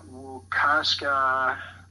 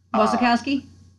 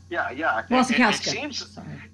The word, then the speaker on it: Wasikowska: And you have the pronunciations down, but Mia Wasikowska. – Wasikowski? – Yeah. – Wasikowska.